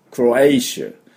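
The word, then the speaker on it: Croatia: クロアティのところね、クロエイシュ。クロエイシュって言うんですよ。ね。エイシュなんです。はい。ですから、クロアチアじゃないですね。クロアイシ、シ、シって音になりますね。これが、要注意です。クロエイシュ、クロエイシュです。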